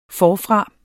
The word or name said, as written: forfra